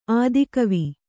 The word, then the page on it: ಆತಿಗುವಿ: ātiguvi